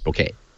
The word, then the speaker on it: bokeh: Now, how do you pronounce it? B-O-K-E-H. – Japanese, the word is properly pronounced bouquet. – Okay. – Okay. – The only reason I say bokeh.